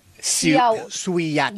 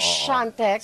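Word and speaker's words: Świątek: Pero yung pagkaka-spell niya... – Siyatek. – Diba? Siyatek. Pero Siyantek. – Siyantek. – Siyantek ang pronunciation.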